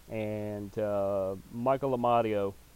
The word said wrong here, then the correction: Amadio: and even though I'm slightly mispronouncing that, it's a D, not an R. Amadio.